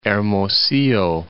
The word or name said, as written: Hermosillo